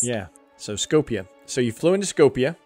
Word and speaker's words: Skopje: Skopje. – That's how they would pronounce it over there. So I've heard it pronounced in different ways, however we want to Americanize it. But I've heard them say Skopje.